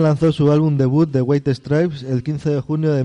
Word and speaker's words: White Stripes: White Stripes. – White Stripes.